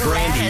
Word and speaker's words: Grande: Do you know we pronounced her last name wrong? It's actually pronounced Brandy. We called her Grande for so long, she just kind of like latched onto her.